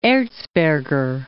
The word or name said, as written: Erzberger